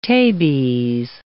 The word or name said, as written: tabes